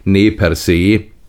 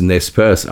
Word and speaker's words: Nez Perce: Die Nez Perce, eigentlich richtig ausgesprochen, ne per se, die durchbohrten Nasen, amerikanisch aber halt immer dann als Nez Perce ausgesprochen,